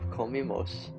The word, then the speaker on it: comimos: Now we have comimos, which matches with the Spanish pronouns nosotros and nosotras. This ending is spelled I-M-O-S and pronounced imos. Finally, we have comieron. This ending is spelled I-E-R-O-N and is pronounced ieron.